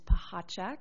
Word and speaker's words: Pahacek: My name is Amy, last name's Pahacek. Little tricky there, not at all like it's spelled, but I won't bore you to death with my background.